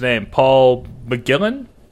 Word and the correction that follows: McGillion: McGillion. McGillion. Okay, I wasn't sure to, if I needed to pronounce the ex the uh, the other I or not.